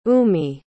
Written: umi

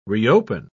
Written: reopen

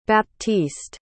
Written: baptiste

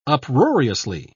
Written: uproariously

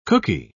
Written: cookie

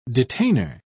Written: detainer

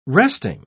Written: resting

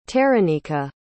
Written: teranika

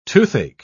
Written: toothache

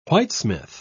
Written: whitesmith

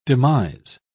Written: demise

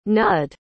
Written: nudd

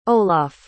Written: olaf

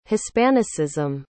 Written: hispanicism

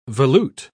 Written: volute

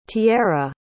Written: tiarra

